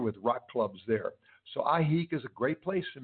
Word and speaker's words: Ajijic: We landed in the state of Jalisco in the small town of Ajijic, that's spelled A-J-I-J-I-C. It's an indigenous word, it's not a Spanish word.